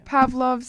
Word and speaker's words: Pavlov: That's how you pronounce Pavlov.